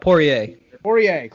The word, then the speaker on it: Poirier: Lead into UFC 257, Conor McGregor versus Dustin Poirier? Did I pronounce it? – Poirier.